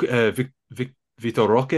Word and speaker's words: Vitor Roque: Oh, actually, he broke Vitor Roque, is that how I pronounce it? – Yeah, he broke his record.